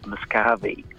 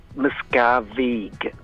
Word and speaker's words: Miscavige: David Miscavige. Miscavige.